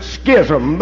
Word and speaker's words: schism: It's spelled S-C-H-I-S-M. Schism. Amen. Or some pronounce it schism, but I think everything's pretty well silent. Amen. When you get past that S, it's schism.